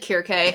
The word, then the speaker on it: Circe: She said Circe, and that's the Latin Circe pronunciation.